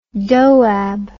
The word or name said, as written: doab